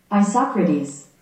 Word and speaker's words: Isocrates: Oh, I got the sound off. – Yeah. Oh, yeah. Ready? – Yeah. – Isocrates. – There you go.